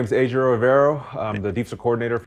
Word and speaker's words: Ejiro: Ejiro. Because he put a little harder. It was a harder J. He said Ejiro. – No yogging. – It wasn't a soft J. – Yeah, no, harder J there.